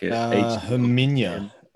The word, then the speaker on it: Herminia: Is it Hermione? Like Herminia.